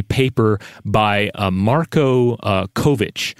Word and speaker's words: Kovic: This is uh, K O V I C with the uh, the last part having a, a ch uh, pronunciation like in chocolate.